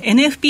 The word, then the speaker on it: NFP: そうですね、あの雇用統計、皆さんが注目される非農業部門就労者数、ノンファンペイロールで N. F. P. って読みます。